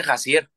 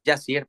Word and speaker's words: Jazzier: porque siempre me, me topo que me dicen Jacier. Entonces, siempre me van a decir, no, es con J, pero es Yacier, pero me gustó que, supieras, pero sí, igual, J-A-Z-Z-I-E-R, Jacier, así, así me encuentro.